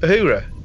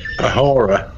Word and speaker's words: Uhura: How do you say it? Uhura. – Yeah. Yeah. – What did I say? – A horror.